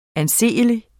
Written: anseelig

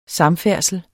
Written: samfærdsel

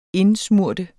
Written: indsmøre

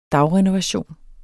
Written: dagrenovation

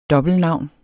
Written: dobbeltnavn

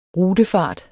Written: RUTEFART

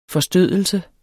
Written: forstødelse

